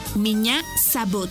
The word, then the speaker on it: Меня зовут: Y se escribe P-R-I-V-I-E-T. Привет. ¡Muy bien! Repite conmigo. Привет. ¡Muy bien! En ruso, me llamo se dice Меня зовут. ¿Y tu nombre? Una vez más. Меня зовут. Por ejemplo, Меня зовут. Esta palabra se escribe Меня. M-I-Ñ-A. Меня. Зовут. Z-A-V. U, T, Sabut, Меня зовут.